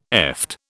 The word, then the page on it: eft: British English